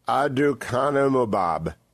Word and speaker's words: aducanumab: It's called aducanumab, A-D-U-C-A-N-U-M-A-B.